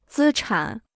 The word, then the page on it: 资产: zī chǎn